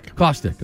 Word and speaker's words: Kostek: Is that how you pronounce it? – Kostek. – Okay.